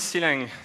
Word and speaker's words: Clang: So what is C-Lang? C-Lang or Clang or Clang, as you wish, you can pronounce that in many different ways.